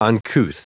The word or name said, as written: uncouth